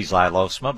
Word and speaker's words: Xylosma: is Xylosma. And it's spelled X-Y-L-O-S-M-A. I had a caller this morning proud of his being able to say Xylosma, but I always tell him it's just like xylophone, X-Y.